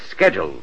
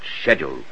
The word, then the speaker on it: schedule: An American says schedule. Only an Englishman pronounces it schedule.